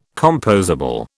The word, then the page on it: compossible: British English